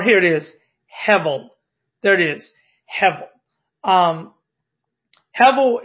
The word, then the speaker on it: Hevel: I believe it's something along the lines of Hevel. Hevel, H-E-V-E-L, is how you would spell the pronunciation of that.